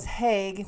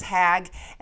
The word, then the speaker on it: Haig: So, for anyone that doesn't know, Shane, you probably don't know, my last name was Haig, which was H, two A's in a row, and a G. And everyone mispronounced it as Hag, and the assholes out there pronounced it in the German way as Hog.